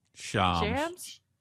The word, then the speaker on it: Shams: Shams.